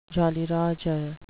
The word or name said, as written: jolly roger